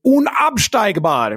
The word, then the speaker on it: unabsteigbar: Unabsteigbar, precis. Det är helt rätt.